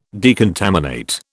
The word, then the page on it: decontaminate: British English